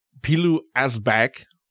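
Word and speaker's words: Pilou Asbæk: Yeah, and of course, over to, I got to say his name right here, Pilou Asbak. – Great job. – There you go. – You nailed that.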